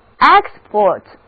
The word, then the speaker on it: export: 如果字母 E 读作 A，而重音挪到了第一个音节，这个时候 export，